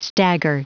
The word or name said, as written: stagger